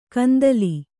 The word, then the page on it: ಕಂದೀಲು: kandīlu